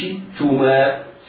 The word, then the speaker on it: شئتما: والتاء مضمومة، والشين في شئتما مكسورة، إياك وأن تنطقها شبه مضمومة. يقرأ القارئ هكذا: وكلا منها رغدا حيث شئتما، لا، بالغ في إظهار كسرة الشين، حيث شئتما، حيث شئتما.